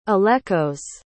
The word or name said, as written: alekos